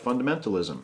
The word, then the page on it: fundamentalism: American English